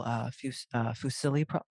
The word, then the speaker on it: fusilli: Anna made a really beautiful fusilli. How do you say that? Is it fusilli? – Fusilli.